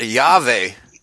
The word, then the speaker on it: Yahweh: The Israelites would not have pronounced it Jehovah. It's not possible. They would have pronounced it Yahweh, spelled with a J and a, and a V or a Y and a W. It doesn't matter. That's the actual pronunciation.